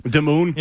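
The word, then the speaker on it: Damoun: Maurice Harris, Bruce Ellington, Braxton Berrios, something called Damoon Patterson, Damon Patterson, however the hell you want to pronounce it. I'm sure he won't even end up making the team. – I'm pretty sure it's Damoon. – Damoon? – Yeah.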